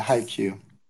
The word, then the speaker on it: Haikyuu: Yeah, Haikyuu.